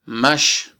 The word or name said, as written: max